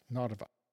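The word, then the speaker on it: Narva (Нарва): At Narva on that map looks like Hapaba in Roman letters, so H-A-P-B-A, but in Cyrillic it's pronounced Narva.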